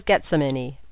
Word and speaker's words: Getsemani: His name is Getsemani, pronounced like saying, get some money, smile, because it was my idea.